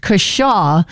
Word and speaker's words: cashaw: And the last one was pronounced Kashaw, but it's spelled C-A-S-H-A-W.